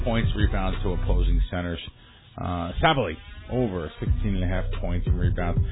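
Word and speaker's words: Sabally: Am I saying it right? Sabali? – Sabali, yeah.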